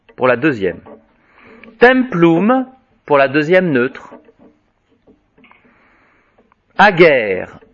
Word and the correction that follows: Ager: adger plutôt, hein, excusez-moi, là je prononce en détruite.